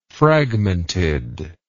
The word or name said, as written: fragmented